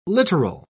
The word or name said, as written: littoral